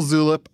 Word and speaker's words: Zulip: Is it spelled like tulip? – Yes, with a Z. Discord, Slack, Signal, Zulip, iMessage, Twitter DMs, Instagram DMs, and regular SMS.